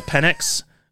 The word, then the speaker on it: Penix: I do want to talk about make sure you say his name right. I know sometimes you mispronounce things, but Michael Penix Jr. Penix. Thank you.